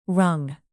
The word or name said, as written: rung